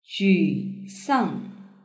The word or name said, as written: jusang